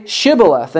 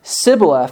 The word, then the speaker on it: Shibboleth: When he said no, they said to him, "Then say Shibboleth." And he said Sibboleth, for he could not pronounce it right.